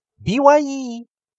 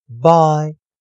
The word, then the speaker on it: bye: 好了，那同学们，我们今天带来生词，第一个生词 b y e bye bye bye 再见，d y e die die die 给点点点染色，所以同学们要记得 y e。你把它视为 i e，那并且发音一样是长元音时，那这两个生词就可以发音了。最后再跟我老老师一起练习 b y e bye bye bye 再见 d y e die die die 给点点点染色，请同学们跟老师一起多加练习。